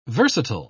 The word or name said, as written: versatile